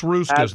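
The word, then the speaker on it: Hruska's: Hey, Haruska's off 71 on the way to Austin. – Just Ruska's. The H is silent.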